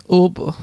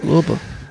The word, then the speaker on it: Uber: Uber? Uber? Heißt es da? Das heißt Uber, da ist es anders ausgesprochen. – Wie wird es ausgesprochen? – Uber. Du musst ein bisschen weinen dabei. Uber.